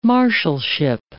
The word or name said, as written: marshalship